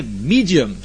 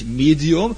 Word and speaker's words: Medium: Und dann gibt es Leute beim Steak, die wollen das gerne Medium, das eine muss englisch ausgesprochen, meint aber dasselbe, nämlich Medium.